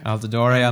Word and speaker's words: Altidore: I believe his name is pronounced Altidore. – Altidore.